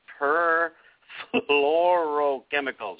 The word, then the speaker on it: perfluorochemicals: Purifluoro chemicals. That's a mouthful. Another class of endocrine disruptors. Use cast iron, stainless steel, and enamel-coated cast iron instead. Why I cannot pronounce this, I have no idea. Perfluorochemicals, there we go, are also found in stain-resistant fabric treatments.